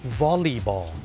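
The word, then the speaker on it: volleyball: วอลลีบอลวอลลีบอลตัว L-E-Y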